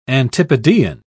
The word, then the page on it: antipodean: British English